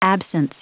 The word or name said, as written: absence